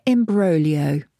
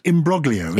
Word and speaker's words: imbroglio: It's spelled imbroglio, isn't it? – But is that not correct? – It's a complicated situation. Imbroglio, it's Italian. Imbroglio. – Imbroglio. – Yes. – I-M-B-R-O-G-L-I-O.